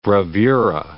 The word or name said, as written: bravura